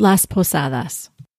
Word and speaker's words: Las Posadas: Yeah, he has a couple good, he has the Night of Las Posadas, if I'm saying that correctly. – Yes, Las Posadas.